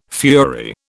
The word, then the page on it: fury: British English